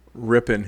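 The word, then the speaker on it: Rypien: Ripien.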